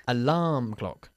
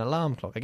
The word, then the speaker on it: alarm clock: Alarm clock. – Alarm clock. – Alarm clock. – Alarm clock. – Enfatizando la segunda sílaba. Alarm clock. An alarm clock.